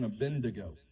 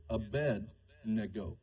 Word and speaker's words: Abednego: How many people have heard about Shadrach, Meshach, and Abednego? That's usually how it's pronounced, right? That's not the man's name, okay? It's Abednego.